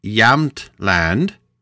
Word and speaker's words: Jämtland: All right, so in the deep forest of, and I think I'm pronouncing this right, Jämtland. It's like Jamland, but I think that the J is a yeah, like a yaw sound.